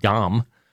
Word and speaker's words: Ngom: It's spelled N-G-O-M. The N is silent. Modu Dom.